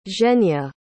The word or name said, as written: zhenya